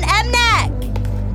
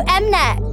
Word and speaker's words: MNEK: Hey, oh, it's Zara Larsson and Mnek. – Wait, who? – You know Mnek. Mnek. Menk. Mnek. – Hey, Mnek. Babe. It's M-N-E-K.